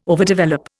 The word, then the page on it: overdevelop: Indian